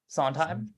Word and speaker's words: Sondheim: Sondheim.